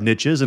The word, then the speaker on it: niche: You know, you hear a lot of different people talking about niches, and I like the idea of niches, and frankly, it's probably better translated or said niche, but it doesn't rhyme with rich.